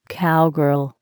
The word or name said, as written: cowgirl